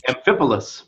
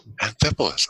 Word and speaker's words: Amphipolis: I've already forgotten how to pronounce the first one. – Amphipolis. – Amphipolis. Okay, anyway, starting at verse 1.